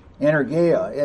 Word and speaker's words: Energeia: Energeia is how it's pronounced. Energeia, and it's the power in exercise, operative.